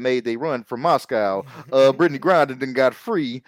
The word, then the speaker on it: Brittney Griner: We talking about Brittany Griner. I said it right this time. Y'all heard me. I didn't say, I left the D out. I omitted the D.